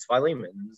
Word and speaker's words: Philemon: I remember as we were going through the books of the Bible, Mom went to Aunt Elaine and said, how do you pronounce this book? And it was Philemon or Philemon. And so I think Aunt Elaine was like, it's Philemon.